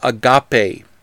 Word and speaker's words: agape: The Greek word Paul uses for love here and throughout the chapter is agape, which rhymes with the top A, or a hot day. It's three syllables.